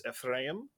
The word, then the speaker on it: Ephraim: So in Hebrew, it's Ephraim.